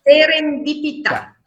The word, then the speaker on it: serendipità: il suo libro Un caso di serenidipità. Scusa se l'ho detto giusto. – Serendipità.